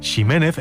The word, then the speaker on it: Ximénez: Así, por ejemplo, la variedad de una uva conocida como Pedro Jiménez se pronuncia así, aunque ese Jiménez se siga escribiendo con X, si bien también se escucha mucho decir Ximénez e incluso Ximénez cuando se pierde ya la referencia de su pronunciación original.